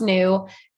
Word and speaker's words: Nous: So the name is actually, it's pronounced new and it means us in French.